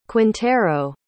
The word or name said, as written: quintero